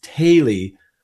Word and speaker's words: Thoele: So my last name's pronounced Taylee, which a lot of people get wrong, but I don't correct them because who cares really? – Right.